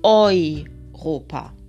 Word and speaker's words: Europa: La primera la podemos encontrar, o sea, la primera sería la EU, como en Europa, lo que pasa que Europa en alemán no se dice como en español, sino Europa.